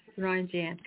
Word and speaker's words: Naranjan: Uh, is it Nar- Naranja? Am I saying that right? Or Naranja? Sorry. Naranjan. Naranjan, correct? – N- Naranjan. Yes, you got it.